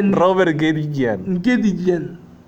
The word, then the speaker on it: Robert Guédiguian: Es Ariane Ascaride, no sé cómo se pronuncia, por Gloria Mundi, una película francesa de Rupert Nguyen. Ah, cabrón, ¿sí se dice, güey? – Robert Guédiguian.